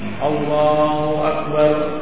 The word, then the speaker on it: Allahu Akbar: Allahu Akbar bukan hu tapi u Allahu Akbar begitu ya.